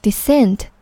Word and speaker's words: descent: 注意这两个单词的重音都是在 cent 之前，读作 descent 和 ascent。